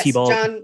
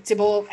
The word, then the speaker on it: Tybalt: John Leguizamo plays T-Bolt. – Tybalt,